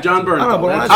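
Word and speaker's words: Jon Bernthal: John Bernthal. Odds are I probably said it wrong. – No, I think that's right, actually.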